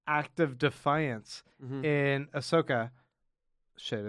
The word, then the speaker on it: Ahsoka: Every time I say it, I think I say it wrong. – You say it differently, but that was right. – Ahsoka and... anyway, Ahsoki. – Ahsoka.